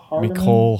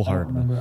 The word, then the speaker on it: Hardman: Um, Miss Missel Mecole Hardeman? Hardman.